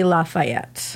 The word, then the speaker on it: Lafayette: Gilbert Dumontier, Marquis de Lafayette.